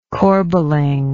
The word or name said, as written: corbeling